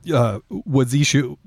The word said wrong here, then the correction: Washizu: I just want to make sure I'm pronouncing this correctly. Excuse me. Washizu.